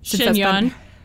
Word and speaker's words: chignon: And I feel like she might wear it in a lower chiffon. – Chignon. – Chignon. Is that how you say it? – Yeah. Chiffon's a fabric. – No, it's chignon.